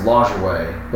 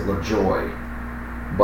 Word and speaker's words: Lajoie: Or I guess some people say Lot, like it's, I know it's pronounced Lajoie, but some people pronounce it LaJoy. But I think it's Lajoie. – L-A-J-O-I-E? – Yeah. Yeah, you can go either way, but from what I understand, it's Lajoie.